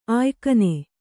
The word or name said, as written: ಆಯ್ಕನೆ